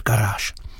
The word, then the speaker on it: Garage: Od roku 93 hrající s upraveným názvem Garage, ale vyslovuje se to samozřejmě pořád Garage.